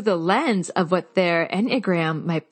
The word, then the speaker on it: Enneagram: Was I pronouncing it right? Enneagram? Is that right? – That's right. Yes.